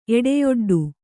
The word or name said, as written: ಎಡೆಯೊಡ್ಡು